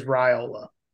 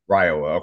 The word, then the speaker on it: Raiola: But I will say to the listeners out there, if you haven't watched Dylan Raiola's tape, and I looked up the pronunciation, it is Raiola. – Raiola, okay.